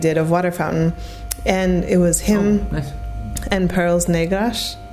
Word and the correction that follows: Perlas Negras: Sorry, no, Portuguese is difficult, yeah. Perlas negras.